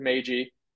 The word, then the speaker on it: Meiji: I don't know if it's Meiji, Meiji, but yeah, it's Meiji.